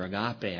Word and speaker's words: agape: There is no g sound, I guess, so it's a little softer, agape.